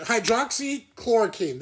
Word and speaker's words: hydroxychloroquine: Hydroxychloroquine.